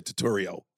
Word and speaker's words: Turturro: You're going to make me fuck it up, but you said Turturro. – Did I say it wrong? – Like a tutorial.